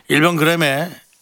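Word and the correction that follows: 인별그램: 오늘 발음이 계속 새네요. 왜 이러지? 인별 그램에 예.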